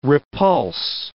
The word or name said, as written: repulse